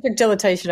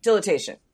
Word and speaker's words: dilatation: We're discussing what is commonly referred to as bloat, also known as GDV, gastrodystolous volvulus. I'm saying it wrong. – Dilatation and volvulus, yes. – There we go. Dilatation.